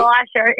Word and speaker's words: Schleicher: Schleicher. It's, no, God, that's worse. No, it's Schleicher. It's said a lot easier than it's spelled.